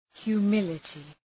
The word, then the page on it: humility: hju:’mılətı